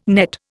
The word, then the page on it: nett: Indian